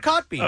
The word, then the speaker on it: Kotb: She told Cotby. – I don't even think that's how you say it. – Yeah, Hoda Cotby.